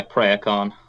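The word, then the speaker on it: préachán: P-R-E-A-C-H-O-N. Is it O-N or A-N? The letter... Yeah, Priacon. – Like pray. – Preach on. – Preach on. – Yeah, a bit like that, yeah.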